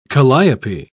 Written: calliope

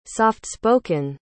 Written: softspoken

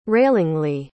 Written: railingly

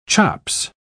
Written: chops

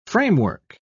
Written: framework

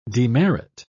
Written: demerit

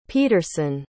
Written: peterson

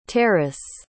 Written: teris

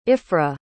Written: Ifrah

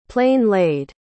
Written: plain-laid

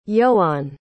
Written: ioan

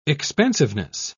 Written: expansiveness

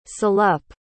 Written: salop

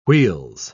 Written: wheels

